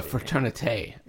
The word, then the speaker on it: fraternité: That's more of a fraternité.